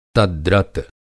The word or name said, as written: तद्गत